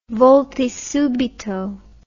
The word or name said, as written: volti subito